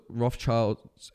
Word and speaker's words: Rothschilds: I feel like, and that's where we're talking about the, you know, the one world government, and anyone that follows the Rothschilds, am I saying that right? – Yeah.